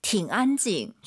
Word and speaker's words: 挺安静: 팅 올라가면 안 돼요. 다시 들어볼게요. – 挺安静. – 저 선생님 발음 어때요? 팅 안 올라가죠? 뒤에가 일성이니까 이 삼성이 반삼성이에요. 挺安静. 이렇게 다른 원어민 선생님들도 아마 똑같이 발음하실 겁니다.